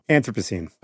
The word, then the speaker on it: Anthropocene: Anthropocene.